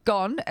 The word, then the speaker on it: scone: The dictionary had the pronunciation as scone, as in gone, but it also recognises the other lesser pronounced bone.